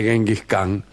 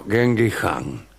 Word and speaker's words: Genghis Khan: La primera es que Genghis Khan, no se dice Genghis Khan, sino Genghis Khan.